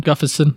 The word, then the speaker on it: Gustafsson: Gustafsson, yeah.